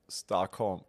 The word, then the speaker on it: Stockholm: Stockholm.